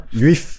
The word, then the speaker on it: Lviv: Lviv, ja. – Sådan, og det er... Det holder staves L-V-I-F. V, ikke? – Ja, lige præcis.